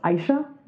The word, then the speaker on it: Aisha: And in Arabic, which is the form of it that I resonate more with, it is usually pronounced Aisha, and it either means she who lives or she who must be obeyed.